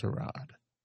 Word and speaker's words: Tyrod: It's pronounced Tyrod.